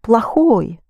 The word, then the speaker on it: плохо: You spell плохой, but you pronounce плохой. Think of plague. Can't think of anything worse than that. Плохой. What is badly, then? Accent the first O.